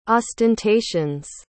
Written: ostentations

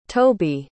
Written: tobey

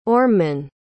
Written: ormeman

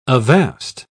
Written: avast

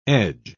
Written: edge

